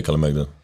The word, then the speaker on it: Kalemegdan: and and one thing that stuck in my mind, and I know I'm going to butcher the name, is the uh, Kalemegdan. – Yeah, Kalemegdan Fortress.